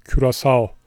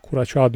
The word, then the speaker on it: Curaçao: Amsterdam'dan Serkan, Kuraçağ'ın okunuşu Türkçe'de Curaçao'dur diyor. Uf, çok farklı ya. Curaçao. Böyle okunduğunda herkes anlar diyor. Çünkü bu da internasyonel okunuşudur demiş. Curaçao.